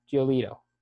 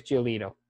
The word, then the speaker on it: Giolito: They were getting no hit by Lucas Giolotto. How do you say his last name again? It's been so long since I've heard from him. Giolito. Lucas Giolito.